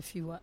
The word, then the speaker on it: Fiwa: C'est le festival Fiwa, c'est comme ça qu'on prononce ? – C'est ça, c'est Fiwa.